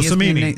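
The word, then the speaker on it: Cimini: Yeah, well, I mean, Rich Kamini, I believe is how his name's pronounced. – Oh, Samini. – Samini, okay.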